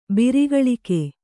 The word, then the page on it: ಬಿರಿಗಳಿಕೆ: kannada